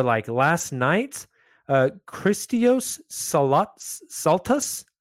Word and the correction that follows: Christos Saltas: I hope I pronounced that right. Christios Saltas. Christos Saltas. Yes. There we go.